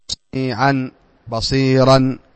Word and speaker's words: سميعا بصيرا: وهو الله سميعا بصيرا، إذن ينطق بالألف عند سميعا بصيرا، واضح؟ فعند الوقوف عليه تقول سميعا بصيرا، تنطق ايش؟ بالالف المنون، وكان الله سميعا بصيرا. عند الوصل لا ينطق، عند الوصل لا ينطق.